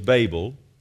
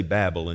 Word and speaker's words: Babel: And then in 10:10 it says, and then the beginning of his kingdom was Babel. We say Babel in Texas, but it's actually pronounced Babel, so you know.